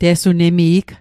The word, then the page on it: te'sunemi'g: Mi'gmaq